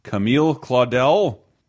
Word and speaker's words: Camille Claudel: Camille Claudel. – Yeah. You said it right. – Good job. – Featuring Gerard Deep Era D-I-U. Mm-hmm. D-U-I.